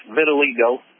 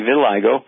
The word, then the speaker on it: vitiligo: Yes, vitiligo. – Okay. – And it's for me. – Okay, well, vitiligo is pronounced more correctly, vitiligo.